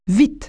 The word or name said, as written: fast